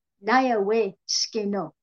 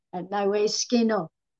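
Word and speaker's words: Nyawëh sgë:nö': It is pronounced Naya We Skeno. And that's, of course, you know, a broken up English. However, Naya We Skeno, I am thankful you are well.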